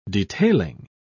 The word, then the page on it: detailing: British English